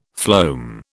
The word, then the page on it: phloem: British English